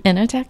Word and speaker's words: Enoteca: Did I say that right? – Enoteca.